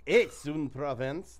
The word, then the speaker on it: Aix-en-Provence: I think, to my surprise, because I looked up the pronunciation on that, I think they actually do pronounce the X, and I was shocked by that. – Aix-en-Provence.